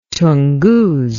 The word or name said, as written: tunguz